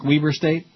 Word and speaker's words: Weber State: Isn't that how you say it, Zach? Weber State? – Yes.